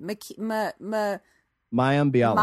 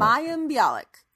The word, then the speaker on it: Mayim Bialik: Mayim Bialik. – Mayim Bialik.